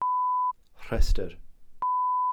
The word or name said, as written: rhestr